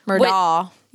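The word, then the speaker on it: Murdaugh: And I still hate saying Murdoch because it's Murdaw.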